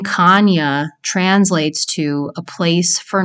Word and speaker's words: Chania: The city of Chania, which is spelled Chania, but it's pronounced Chania, is where piracy began.